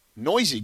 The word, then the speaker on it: Neuse: It's spelled Neuse, but he is Noisy.